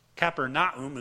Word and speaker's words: Capernaum: So, he provides a second example in Matthew 11:23, and thou, Capernaum, Capernaum is how they pronounce it over in Israel, which art exalted unto heaven shall be brought down to the grave.